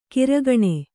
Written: ಕಿರುಕಣ್

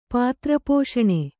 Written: ಪಾತ್ರಪೋಷಣೆ

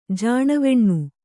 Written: ಜಾಣವೆಣ್ಣು

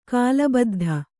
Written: ಕಾಲಬದ್ಧ

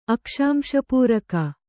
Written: ಅಕ್ಷಾಂಶಪೂರಕ

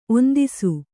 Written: ಒಂದೆಸೆ

